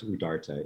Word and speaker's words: Udarta: Is that how you pronounce it? – Yes, it's pronounced Udarta. It's a Hindi word, actually.